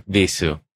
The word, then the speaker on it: Vheissu: Vheissu. Yeah. – Yeah, because there's uh, some people say it's uh, pr- pronounced because it's uh, it has, has a German meaning. Mm. Wie heißt du?